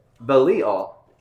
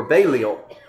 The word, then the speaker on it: Belial: And he says this, "What accord has Christ with Belial," or Belial is how I pronounced it before I understood the correct pronunciation. Belial.